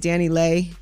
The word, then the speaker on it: Dani Leigh: She's also been dealing with people saying her name wrong. It's Dani Leigh.